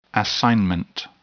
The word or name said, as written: assignment